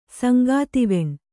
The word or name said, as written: ಶಂಖಧ್ವಾನ